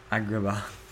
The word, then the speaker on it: Agrabah: Agrabah.